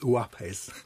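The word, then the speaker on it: Uaupés: which I'm reliably informed should be pronounced Uapes.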